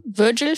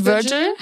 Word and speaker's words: Virgil: Ich hoffe, ich habe ihn jetzt richtig ausgesprochen. – Virgil vielleicht? – Virgil. Ja, genau.